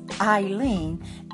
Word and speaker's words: Ileane: If this is your first time here, my name is Ileane, and that's spelled I L E A N E.